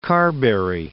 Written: Carberry

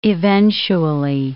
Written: eventually

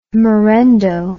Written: morendo

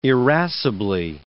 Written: irascibly

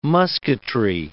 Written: musketry

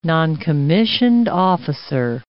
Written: noncommissioned officer

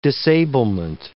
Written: disablement

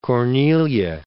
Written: Cornelia

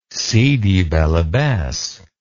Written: sidi bel abbes

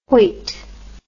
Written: Hoyte

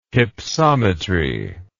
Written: hypsometry

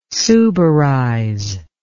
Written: suberize

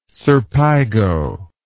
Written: serpigo

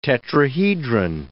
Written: tetrahedron

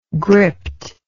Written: gript

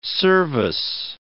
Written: service

